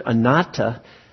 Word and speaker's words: anatta: And so going back to this word anatta, the A, that A sound is a short A, the A is a negation. So it's not an atta.